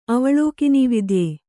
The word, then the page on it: ಅವಲೋಕಿನೀವಿದ್ಯೆ: kannada